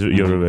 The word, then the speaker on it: Yoruba: So it's Yoruba.